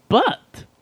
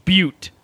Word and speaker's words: butte: What is the sweeping butt? – I believe that is pronounced sweeping butte.